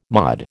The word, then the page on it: MOD: American English